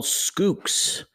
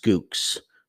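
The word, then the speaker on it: Schoox: I like a vendor called Skooks. And get ready for it. S-C-H-O-O-X. S-C-H-O-O-X. They're pronounced Skooks. So when you're looking for them on the web, it's S-C-H-O-O-X.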